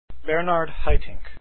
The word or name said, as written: haitink